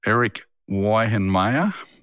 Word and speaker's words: Eric Weihenmayer: Eric Weihenmayer is how you say it.